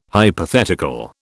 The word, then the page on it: hypothetical: British English